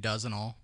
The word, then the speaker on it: Dozenal: Well, today we're talking about the, and I don't know if I'm pronouncing this right. The Dozenal Society. – Sounds right. I've been saying Dozenal. – Dozenal. Have you heard of this? – Spell it. – Dozen with an AL at the end. – Okay. – Like a dozen eggs.